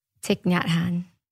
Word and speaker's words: Thich Nhat Hanh: The reason why I love Thich Nhat Hanh, shout out to Julian for teaching me how to pronounce it correctly. I hope I said it right. – You did.